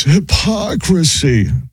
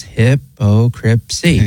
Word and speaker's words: hypocrisy: It's not, it's hypocrisy. – No, it's hypocrisy.